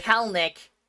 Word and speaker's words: Kelenic: Yeah, 096 for poor Jared Klenick. Or Kelenic. Is that how you spell his name or is pronounce his name? – I think Kelenic. It's Kelenic.